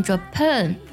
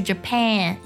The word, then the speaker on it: Japan: 就是 Japan 就成那个 A 的音了，这个音就错了，所以它正确读音是 Japan。